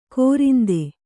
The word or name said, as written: ಕೋರಿಂದೆ